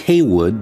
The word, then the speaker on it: Heyward: Pronounced Heywood, though it appears Heyward on the declaration that he signed, he was being released as part of a prisoner exchange and was about to get his freedom.